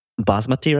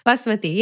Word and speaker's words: basmati: Examples of this are jasmine rice, or I might butcher this pronunciation, basmati rice. – Ah, you actually said it right this time. Yeah, basmati. Yeah, perfect. Good. Thumbs up.